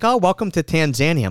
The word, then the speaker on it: Tanzania: No, it's Tanzania.